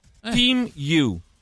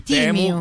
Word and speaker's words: Temu: Allora, io voglio imparare questo Temu, si pronuncia Team You. – Team You. – Con la I. Team You. Quindi non dite più Temu, che fate per ride se dite Temu.